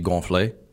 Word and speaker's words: gonflé: If you've eaten a lot and, you know, you got a full belly, you can say you gonflé. – What was that? – Gonflé. – Gonflé? – Gonflé. – Okay. – Two syllables. I said gonflé.